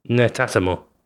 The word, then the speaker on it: Netatmo: Netatmo? Yep. How do you say it? Right. – Badly. Most of us, I think, we're getting it wrong, aren't we? – Netatmo.